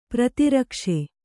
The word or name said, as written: ಪ್ರತಿರಕ್ಷೆ